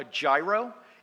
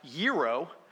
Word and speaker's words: gyro: Now, a lot of Americans mispronounce this dish. They'll call it a gyro. It is actually pronounced gyro.